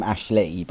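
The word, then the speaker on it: Ashleigh: Well, I don't have a Christmas link to my name, but I am Ashley,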